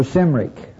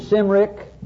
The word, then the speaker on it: Cymric: You can check your pronunciation for Cymric. You have two options. With a soft sound, pronounce it Cymric or Cymric. Both of them allowed.